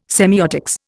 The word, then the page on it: semiotics: Indian